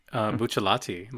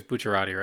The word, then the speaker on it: Bucciarati: It's weird with what you emphasize as well, because in Japanese it's Bucciarati, so they emphasize the cha part, So I'd say like I'd say like Bucherin.